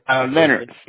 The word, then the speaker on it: Leonard: Leonard Slim. – Minard? Or? – Yeah. – Spell that, please. – L-E-O-N-A-R-D.